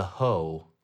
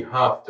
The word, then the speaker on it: hough: But apparently, the scientific name for a knee pit is the popliteal fossa, but it is also commonly referred to as the hoe, like H-O-U-G-H. – That's weird. – Why? Yeah, I've never heard that. – Nope. – Hoe? How else would you pronounce that? – Could be Huff, depending on where you're from.